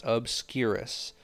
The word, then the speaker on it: obscurus: An obscurus, okay, pronounced obscurus, is a very deadly and uncontrollable entity, okay, that develops from a magical being who possesses magic.